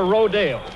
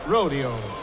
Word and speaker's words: rodeo: so why don't you tell them how to pronounce it, rodeo or rodale? – Well, it seems to depend on where you're at the time, Bob, but all the cowboys call it rodeo.